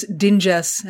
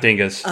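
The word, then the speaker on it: Dingess: The exact same team, directed by Jennifer Getzinger, written by Chris Dinges, and that's how I'm pronouncing it. – Not Dinges.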